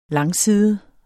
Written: langside

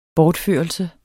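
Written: BORTFØRELSEN

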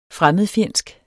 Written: fremmedfjendsk

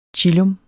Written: chillum

